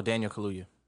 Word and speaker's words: Kaluuya: Starring Kiki Palmer, Steven Yeun, and Daniel Kula. I don't know how to pronounce that. Kaluuya. – Oh, Daniel Kaluuya.